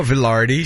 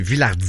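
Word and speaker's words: Vilardi: Il évoluait sur un trio formé de Mark Scheifele et Gabriel Villardi. Pardon. – Villardi. – Ouais, j'avais envie de dire Villardi comme les commentateurs français. – C'est pas Villardi, c'est Villardi.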